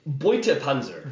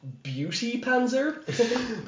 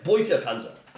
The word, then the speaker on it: Beutepanzer: Beutepanzer. It's that Beauty Panzer? But yeah, Beutepanzer.